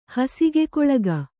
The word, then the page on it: ಹಸಿಗೆಕೊಳಗ: kannada